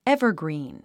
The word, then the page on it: evergreen: évərgrìːn; エバァグリーン